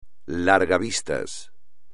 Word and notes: largavistas: espagnol

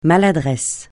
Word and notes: maladresse: francais

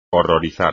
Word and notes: horrorizar: espagnol

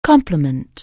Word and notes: compliment: anglais

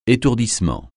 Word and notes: étourdissement: français

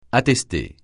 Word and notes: attester: francais

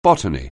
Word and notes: botany: anglais

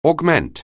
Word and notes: augment: anglais

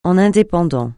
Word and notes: en indépendant: français

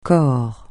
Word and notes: corps: francais

